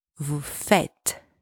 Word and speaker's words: vous faites: Second, make sure the S from vous faites is silent. It's not vous faites. It's at the end of the word, so it's silent. It's vous faites.